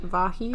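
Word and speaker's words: Vahe: Yeah, and his father, Vahi, did you say that's how you pronounce it? Vahi? – I think so, yeah, because it's V-A-H-E, That's what I thought too.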